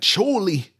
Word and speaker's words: Chollie: It's Charlie, but spelled like C H O L L I E, like Chorley. Chorley. Chorley.